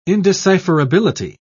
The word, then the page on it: indecipherability: British English